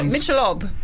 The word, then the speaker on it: Michelob: Am I just saying it Mikkelob? Michelob.